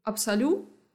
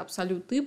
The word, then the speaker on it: абсолю: И вот абсолю, которое, ну, окей, кто-то может назвать это абсолюты, потому что пишется абсолют, но как бы по-французски не читается, поэтому как бы выбирайте сами, хотите так, но дело в том, что масло — это паровая дистилляция в основном.